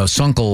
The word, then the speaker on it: Sunkel: So it's interesting because when I asked Gwen how she pronounces her last name, she said it's Sunkel like uncle.